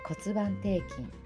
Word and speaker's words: 骨盤底筋: えー、骨盤底筋肉の筋と書いて骨盤底筋と読みます。